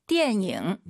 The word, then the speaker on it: 电影: 电影. – 네, 니은 발음 들리면 안 돼요. 이응입니다. 띠엔잉 꼭 기억하시고요.